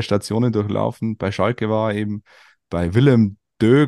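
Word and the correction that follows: Willem II: glaube ich, spricht man es aus. – Willem Twee. Willem Twee.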